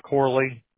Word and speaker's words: Corley: It's Corley. – Could you spell that? – C-O-R-L-E-Y. – And is Mark M-A-R-C, M-A-R-K? – K.